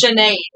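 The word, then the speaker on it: Sinéad: So, if I was looking at this, I would say, like, how it says you're not supposed to say it. Sinead. But it's Sinead.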